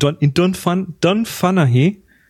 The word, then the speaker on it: Dunfanaghy: Also wir werden mit Sicherheit wieder uns wir treffen uns in Dunfanaghy. Das schreibt sich komisch, aber so ähnlich spricht sich's.